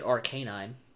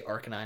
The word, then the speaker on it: Arcanine: I used to pronounce it Arcanine, and then I heard you say Arcanine. I was like, okay, fine, I'll do that. – Well, Arcanine sounds a lot better, and that's actually how you pronounce it.